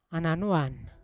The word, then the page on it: ananovon: Yawa